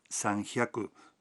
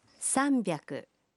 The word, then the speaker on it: sanbyaku: 300 no se pronuncia sanhyaku, sino sanbyaku.